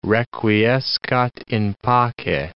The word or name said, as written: requiescat in pace